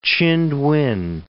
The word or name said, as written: Chindwin